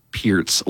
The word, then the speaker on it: Peart: Dallas from EP says, morning, Mosho, please have Buzz pronounce Neil Peart's last name correctly. It's pronounced as Peart. Yeah, there are several interviews with him saying his last name as Peart.